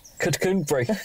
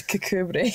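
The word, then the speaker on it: Kirkcudbright: Cucumbri. – It's spelt Kirkcudbri, but it's Cucumbri.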